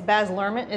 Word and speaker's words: Baz Luhrmann: Well, especially something, someone like, is Baz Luhrmann, is how you say his name? – Yeah.